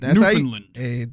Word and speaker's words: Newfoundland: But I think technically it's like Newfoundland. – That's how you pronounce it.